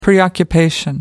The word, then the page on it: preoccupation: American English